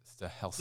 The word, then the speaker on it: Stahelski: Chad Stahelski. I don't know. How do you say that? – Sure. – Chad Stalinsky. – Stahelski. – There's no N in there.